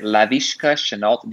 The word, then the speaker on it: Laviska Shenault: Meek took, let me see if I can pronounce his name right, Laviska Shenault Jr. Yeah, you did.